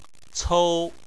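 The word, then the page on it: cou: tone 1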